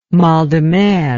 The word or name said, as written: mal de mer